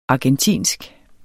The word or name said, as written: argentinsk